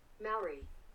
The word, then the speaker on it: Maori: Maori.